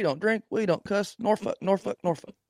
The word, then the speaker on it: Norfolk: Is that how you pronounce that? – We don't drink. We don't cuss. Norfolk.